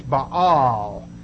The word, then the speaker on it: Baal: You've often seen in the New Testament the word often pronounced Baal. That's the popular pronunciation. Accurately, it's Baal.